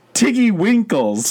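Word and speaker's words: Tiggywinkles: And so he alerted the Twiggly Wiggles Wildlife Hospital. – I think you said that wrong. – Did I? – The Twiggly Wiggles? – Sorry, the Tiggy Winkles.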